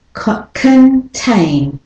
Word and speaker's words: contain: Contain. So the o is the schwa syllable, con, kun. And the tain is the strong syllable. Contain. Contain. So the rhythm is d-da.